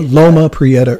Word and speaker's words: Loma Prieta: the Prieta, is that how you say it? Yeah, the Loma Prieta earthquake, yes.